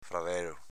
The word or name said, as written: fradelo